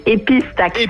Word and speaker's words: épistaxis: Épistaxis. – Épice, bon, on prononce le S.